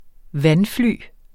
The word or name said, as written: vandfly